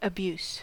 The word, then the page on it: abuse: American English